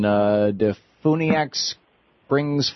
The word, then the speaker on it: DeFuniak Springs: Michael in Defuniak Springs, Florida.